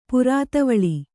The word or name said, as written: ಪುರಾತವಳಿ